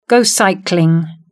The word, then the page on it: go cycling: British English